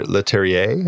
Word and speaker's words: Leterrier: And I mean, just kind of speaking to the way that these things unfold, like I think it was Louis uh, Leterrier, Leterrier, is that how you say his name? Yeah.